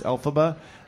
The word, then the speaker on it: Elphaba: Like elf, like alphabet, but it's Elphaba.